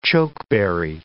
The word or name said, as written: chokeberry